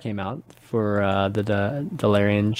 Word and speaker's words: Dalaran: But chapter 3 is Streets of Dalaran, and then chapter 4 how do you pronounce Dalaran.